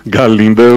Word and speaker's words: Galinda: Galinda, o Gá é mudo, né? – Gá.